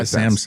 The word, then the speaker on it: Hisense: That is Hisense.